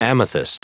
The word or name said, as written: amethyst